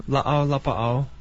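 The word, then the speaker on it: la'au, la'ba'au (lāʻau lapaʻau): And Gabriel is an herbalist, a nutritional herbalist, and also practices and studies la'au, la'ba'au. Did I pronounce that right? – Mm-hmm. – Almost? Why don't you pronounce it? – Well, la'au, la'ba'au.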